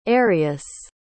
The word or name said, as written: arius